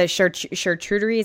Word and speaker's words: charcuterie: Now, you did mention a word in there, the charcuterie. Is that how you pronounce it? – Mm-hmm.